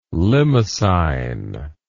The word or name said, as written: limacine